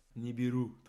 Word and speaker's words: Nibiru: Et je pense aussi à Nibiru, je le prononce bien Nibiru, très bien.